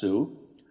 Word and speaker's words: SAULT: And it's a bit of an interesting situation because it's one collective called SAULT, S-A-U-L-T, or in these parts pronounced perhaps Sue.